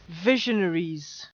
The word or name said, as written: visionaries